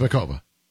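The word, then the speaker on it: Muchová: Muchova. – So I watched the entire thing. They never pronounced the C. So it's Makova. That's how they say it, because she's Czech or whatever.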